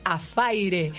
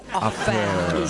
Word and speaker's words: affair: No, chicas, Muñecas se muere porque nosotros decimos Afaire. Affair. Y Muñecas dice que tenemos que decir Affair.